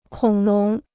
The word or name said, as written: 恐龙